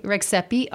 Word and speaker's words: Rexhepi: I've got my Facebook page and all my social media, Instagram at Tringa Rixepi, and Twitter, and I post about all my shows and everything. So you better spell that last name. – So Tringa, T-R-I-N-G-A, Rixepi, R-E-X-H-E-P-I.